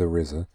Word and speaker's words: RZA: the RZA, who strives to unite a dozen young black men. – I'm so proud of you for saying that correctly, by the way.